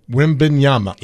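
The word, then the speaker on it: Wembanyama: So Wimbenyama. – Yeah, that's good. – Wimbenyama.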